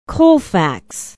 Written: Colfax

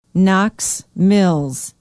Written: knox mills

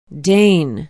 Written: Dane

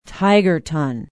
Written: tigerton

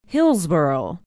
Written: hillsboro

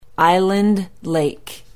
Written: island lake

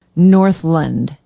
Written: northland

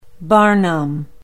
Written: barnum

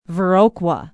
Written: viroqua

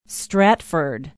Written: stratford

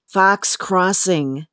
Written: fox crossing

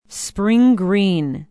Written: spring green

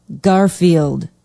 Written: Garfield

